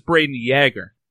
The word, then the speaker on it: Brayden Yager: And for the last couple of seasons, Anytime I see you on TV, it seems to get mispronounced. And it is a mispronunciation, right? It is Brayden Yager. – Yeah, it is Yager. I know people, with the whole Jaromir Jagr and they see it as Yager, but so it is actually Yager, but people tend to say Yager.